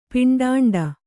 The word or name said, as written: ಪಿಂಡಾಂಡ